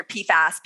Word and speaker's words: PFAS: PFAS, is that how you say it? – But yeah, like, well, you could say if, fluorinated chemicals or PFAS. PFAS is just what everyone will understand.